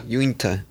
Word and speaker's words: Uinta: Why do you gotta say it like that? – I don't know how to say it, bro. – Unita? – Unita? Uinta. Unta. – Uinta. – Uinta Brewing Co.